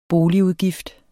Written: BOLIGUDGIFT

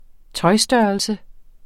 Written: tøjstørrelse

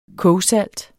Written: kogsalt

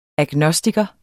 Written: agnostiker

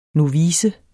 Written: novice

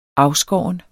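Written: afskåret

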